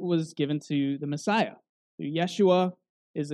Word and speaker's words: Yeshua: Now, Jeshua, or Yeshua, as it would have been pronounced, it's an alternative spelling of Joshua, or Yahshua.